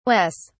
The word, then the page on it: wes: British English